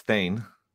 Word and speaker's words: Thayne: I didn't hear you pronounce it, but it's Thayne.